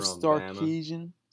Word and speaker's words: Sarkisian: Steve Sarkisian.